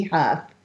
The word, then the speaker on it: Huff: And now, without any further ado, speaking of miracles, I want to bring on our show the amazing Claire Candy Huff. Thank you so much, Candy, for being here with me. And did I say your name right? – You did. It's Huff as in cough.